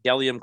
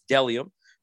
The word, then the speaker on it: Bdelliom: That's B-D-E-L-L-I-O-M, pronounced Delium. Those of you that got my shirts know that it is an odd spelling, but you see what I'm talking about. Again, it's Delium, B-D-E-L-L-I-O-M.